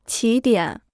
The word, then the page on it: 起点: qǐ diǎn